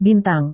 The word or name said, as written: bintang